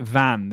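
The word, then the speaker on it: Vannes: I think you pronounce it Van. The town is Van.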